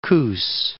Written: Coos Bay

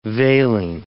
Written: veiling

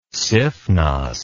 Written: siphnos

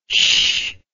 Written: shh

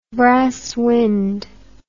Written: brass wind

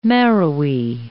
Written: marrowy

